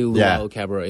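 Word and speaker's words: Luwawu-Cabarrot: Luau Cabarrot? – Yeah, Timothy Luau Cabarrot, yeah.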